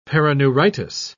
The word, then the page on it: perineuritis: British English